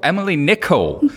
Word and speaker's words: Nicolle: Of course, we've got Emily Cole, uh, Emily Cole, Emily Nicolle, who's technology reporter from City AM.